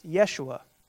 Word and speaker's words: Yeshua: So in Hebrew, the English name for Joshua is actually pronounced Yeshua.